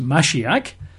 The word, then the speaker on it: Mashiach: or Hebrew it's pronounced Mashiach, M-A-S-H. I-A-C-H is how you write it in English, Mashiach, M-A-S-H, I-A-C-H, Mashiach.